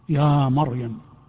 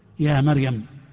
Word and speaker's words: مريم: أنا أقول للجميع هذا الكلام، كلمتان يكثر الخطأ فيهما، فرعون ومريم، يخطئ كثير من الناس فيفخم راء فرعون ويرقق راء مريم، يا مريم، ويرقق راء مريم فيقول يا مريم، يا مريم اقنتي لربك. والصواب العكس فيهما، الصواب العكس فيهما.